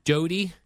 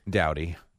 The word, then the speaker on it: Doughty: Of Kings, the defenseman, Drew Doughty. – Doughty. Good try, though.